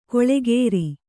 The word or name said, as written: ಗಳೆಕಾರ